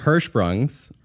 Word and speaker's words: Hirschsprung's: Is that a good pronunciation of that? – Yeah, I think I nailed it. – Hirschsprung's.